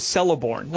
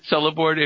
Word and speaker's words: Celeborn: Did you realize that Celeborn, if you look at one of the Wikipedia whatever entries, that it's a hard C at the beginning? – Yes. – Celeborn? – Yeah.